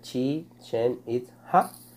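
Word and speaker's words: Chichén Itzá: De los sabios o brujos del, Del agua que se, y se pronuncia Chichen Itza.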